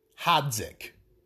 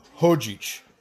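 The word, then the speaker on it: Hadžić: That's how an American would say it. Hadzik. But obviously the pronunciation of it would be Hojic.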